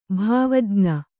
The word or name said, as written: ಭಾವಜ್ಞ